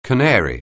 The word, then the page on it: canary: anglais